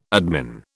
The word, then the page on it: admin: British English